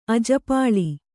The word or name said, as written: ಅಜ್ಜಪಾಲು